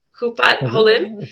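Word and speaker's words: Hupat Holim (קופת חולים): Hupat Holim? – Yeah, it's an HMO, yeah. – Did I say it correctly? – Yeah, you said correctly.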